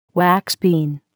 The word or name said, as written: wax bean